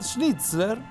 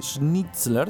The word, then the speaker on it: Schnitzler: Lo fundan Konrad Schnitzler, creo que se dice así, Schnitzler, sí, Hans Joachim Roedelius y Boris Schack.